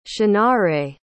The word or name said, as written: shunnareh